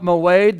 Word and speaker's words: moed: It's spelled M-O-E-D, moed. Say moed.